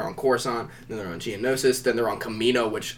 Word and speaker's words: Geonosis: Geonosis.